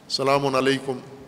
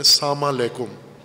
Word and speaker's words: سلام علیکم: جیسے سلام کرتے ہیں ہم سلام علیکم البتہ اپنے لہجے میں بعض پنجابی لہجے میں سلام کرتے ہیں یا غیر پنجابی میں ساما لیکم ساما لیکم نہ ساما کا کوئی معنی ہے